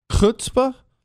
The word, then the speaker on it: chutzpah: Chutzpah? Did I say that right? I did? Is that not, that's closer. Oh, that was right.